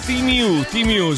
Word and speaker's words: Temu: Temu è Timiu, si pronuncia così.